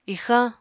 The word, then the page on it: ixañ: apatani